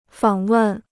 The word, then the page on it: 访问: fǎng wèn